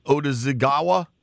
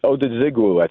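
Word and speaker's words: Odighizuwa: Cowboys D tackle, Osa, I don't know how to say the last, Odazigawa, I think. – It's Odazigwu, I think it is, but you know you're close.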